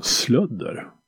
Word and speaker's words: Slödder: Så det är mitt stora nöje att få hälsa välkommen till eh, eh, Heavy Undergrounds podcast från Slödder. Uttalas det så? – Ja, fast den gärna med lite värmländskt schvung i det så det blir slödder.